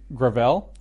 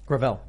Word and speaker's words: Gravel: So this is, it's pronounced Gravel. – Gravel.